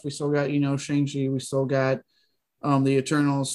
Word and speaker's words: Shang-Chi: Well, first off, I did hear it pronounced. It's Shang-Chi.